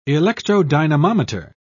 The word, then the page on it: electrodynamometer: British English